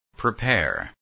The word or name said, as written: prepare